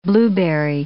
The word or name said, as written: blueberry